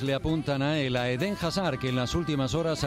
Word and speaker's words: Eden Hazard: Ya sabéis, presentación galáctica esta tarde a las 7 en el Bernabéu de un futbolista cuyo nombre se pronuncia como escuchamos al propio futbolista. – Je suis Eden Hazard, je suis Eden Hazard, Eden Hazard, Eden Hazard.